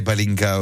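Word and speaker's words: Pálinka: Alors, je ne sais pas si je le prononce bien, mais est-ce qu'il y aura Twika et Palinka aussi? – Exactement.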